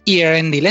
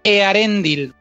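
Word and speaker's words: Eärendil: No es Earendil, es Earendil, porque el élfico, curiosamente, se pronuncia muy parecido al castellano.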